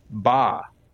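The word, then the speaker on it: ba (八): It's the luckiest because it's pronounced ba, which sounds similar to the Chinese word fa, which means prosperity.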